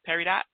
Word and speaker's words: Peridot: Peridot. I don't think you pronounce the T.